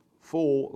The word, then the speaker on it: foal: Binding his foal, not fool like you're a fool, foal like a young ass.